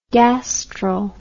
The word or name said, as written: gastral